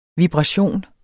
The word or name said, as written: vibration